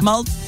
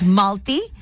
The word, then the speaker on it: Malti: I mean, I'm sure I'm saying a little bit more Indian. – Yeah, you are. – But it is an Indian name. – How do you spell it? – Malty. M-A-L-T-I, if I say it the white way. But it's Malty.